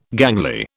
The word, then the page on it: gangly: British English